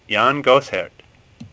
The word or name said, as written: Gossaert